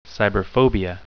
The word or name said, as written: cyberphobia